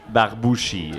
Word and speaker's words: Barbouchi: j'ai vu mon nom écrit en phonétique, là. En phonétique. – Barbouchi. – En trois syllabes. – B-A-R-B-O-U-C-H-I. – C'est ça. Et elle l'a quand même moffé. – Calvasse. – Ouais. Alors... – Mais qu'est-ce qu'elle a dit? – Elle a dit genre Barbouski ou...